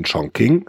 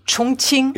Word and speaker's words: Chongqing: Es gab diese Übung Combined Aid, deutsche Sanitätskräfte, chinesische schon vor drei Jahren, damals in Chongqing. Habe ich das jetzt richtig ausgesprochen? Nein? – Chongqing.